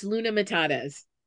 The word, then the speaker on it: Luna Matatas: And can you say your name with the correct pronunciation? – Yes, totally. It's Luna Matatas. – Luna Matatas. And you'd think that that would rhyme right off the tongue because of the Lion King, yet it took me 16 tries to like get it right.